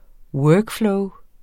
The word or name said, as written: flow